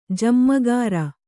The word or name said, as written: ಜಮ್ಮಗಾರ